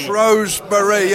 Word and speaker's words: Shrewsbury: Shrewsbury, yeah, with the extra syllable there.